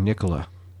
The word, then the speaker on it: Nikola: I'm assuming Nikola.